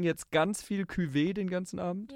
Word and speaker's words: Cuvée: den Cuvée. – Cuvée. Echt? Mhm. – Habe ich es immer falsch gesagt.